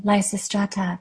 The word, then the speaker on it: Lysistrata: and that's the one that you called Lysistrata. I think that's how you pronounce it. – Mm-hmm. Exactly, Lysistrata.